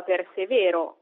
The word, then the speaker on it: persevero: Per esempio, se in italiano diciamo mordere, il verbo latino è invece mordere con la E lunga, ridere invece di ridere, oppure verbi come persevero in italiano, che in latino però si pronuncia persevero, o preparo e preparo.